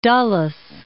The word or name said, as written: Dulles